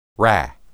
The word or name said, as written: ra